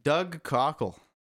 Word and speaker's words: Doug Cockle: Doug Cockle.